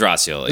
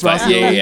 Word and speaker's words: Roscioli: It was Roscioli. It's Roscioli. Yeah.